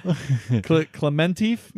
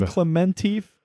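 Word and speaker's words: Klementieff: First, we have Palm, and I'm probably going to totally butcher this last name, Camilla Clef. – Clementif, maybe? – There you go. – Palm Clementif.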